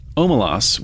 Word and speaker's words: Omelas: Omelas, which is pronounced Omelas. She spells it out for you.